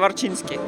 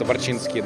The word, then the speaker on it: Варчинский: Она так и читается, Варчинский. Она так и читается, Варчинский, да. – Отлично. А, окей, Юр, тогда представься, расскажи, чем ты сейчас занимаешься. – К- коротко. Да, меня зовут Юрий Варчинский.